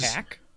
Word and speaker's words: Pac: Puck. – Yeah. Brandi Rhodes. – Pack? Puck? – Pac. Like X-Pac.